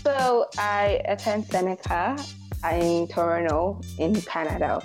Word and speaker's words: Toronto: But here, apparently, T is silent. Second T is silent. So it's Toronto.